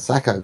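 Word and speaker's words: Sako: If Zaha's got it on the other wing, there's three players, and the same with Sarko. – Sacco. – I didn't say it right then.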